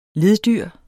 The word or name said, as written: leddyr